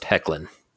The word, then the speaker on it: Hoechlin: Editor's note, it's pronounced Hoechlin.